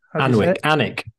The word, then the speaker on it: Alnwick: Alnwick. You say it like, even though it's Alnwick. – Like Flitwick. – Like Flitwick, yeah. We're going up to Alnwick, which has a castle.